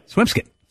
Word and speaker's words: Swampscott: It's spelled Swampscott. – Yeah. – Swimskit.